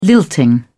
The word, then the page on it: lilting: British English